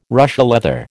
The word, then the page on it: Russia leather: American English